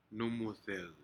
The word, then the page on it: numudec: Aneityum